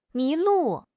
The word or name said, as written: mi2 lu4